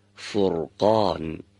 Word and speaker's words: فرقان: دقت داشته باشید اگر حرف را دارای سکون باشه و قبل از اون یکی از دو حرکت فتحه و یا ضمه قرار داشته باشه، در همچین مواقعی باید حرف را ساکن رو درشت بخونیم. مانند فرقان. و اگر قبل از اون، یعنی قبل از را ساکن، کسره باشه، باید را سکوندار رو نازک تلاوت کنیم و تلفظ کنیم.